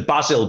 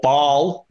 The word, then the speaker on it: Basel: against, oh, right, how are we pronouncing Basel, Baz? Are we going Basel or Baal? What do you want to do? Baal? – Baal, isn't it? I would call them Basel, but I'm sure they're supposed to be called Baal.